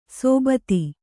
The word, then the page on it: ಸೋಬತಿ: sōbati